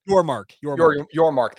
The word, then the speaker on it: Yormark: Is it Brett Yormaker? Is that how you say it? – Yormark. – Thank you. Yes, Yormark, which if we do end up joining that the Big 12, I'm going to have to be sure I get that right.